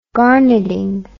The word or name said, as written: garneting